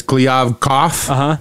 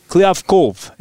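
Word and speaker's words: Kliavkoff: So the English pronunciation is Kliavkov, like coughing. – Okay. – In the Russian pronunciation, there's a hard V at the end, so Kliavkov, is that right?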